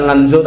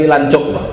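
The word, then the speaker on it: Lanzhou: Ini bacanya bukan Lanzo tapi Lancok. Pas saya ngomong Lanzo itu disalahkan orang sana. Ini bacanya Lancok katanya.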